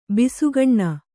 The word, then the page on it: ಬಸಕ್ಕನೆ: basakkane